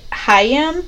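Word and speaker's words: Haim: Uh, but they so I think you say it Hayam because it's their last name and it's H A I M is how you spell it.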